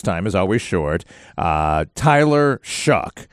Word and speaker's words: Shough: And it's going to confuse people because his last name is not spelled how it's pronounced, but quarterback out of Louisville. – Tyler Shuck is so funny. He came to Manning Passing Academy in that S-H-O-U-G-H, and then they had underneath it in parentheses Shuck.